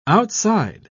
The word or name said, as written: outside